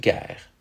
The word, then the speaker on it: guerre: Today, those words are pronounced Gaipe and Gaire.